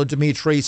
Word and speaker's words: Dimitri: Go to Rumble, download the app, start enjoying it, follow Dimitri, subscribe, follow whatever they ask you to do.